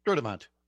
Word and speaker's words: Sturtevant: How do you say, is it Sturtevant? – Sturtevant. Now okay. Watch, everybody's going to go, no, it's Sturtevant.